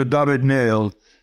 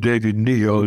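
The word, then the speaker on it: David-Néel: I loved Alexandra David-Niel, if that's pronouncing her name right. – Yes, that's right. The pronouncing correct, Alexandra David-Niel. She was one of the most unlikely, but also extremely well-informed traveler, explorer. She was actually in French, so she's not called David Neal, as some people think, but David Neal.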